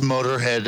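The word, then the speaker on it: Motörhead: It's still pronounced Motorhead.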